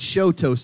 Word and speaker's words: Choteau: And my first time there, I thought it was Chateau. And I'll never forget, I said, it's good to be in Chateau, Oklahoma. I'd never been there before. I didn't know how to pronounce it. Some lady took off her shoe and said, it's Choteau. See, I'm showing you my toe.